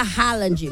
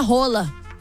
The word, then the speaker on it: Haaland: Uma curiosidade que eu pesquisei também: todo mundo fala Haaland, mas o nome dele se pronuncia Rola.